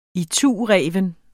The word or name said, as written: itureven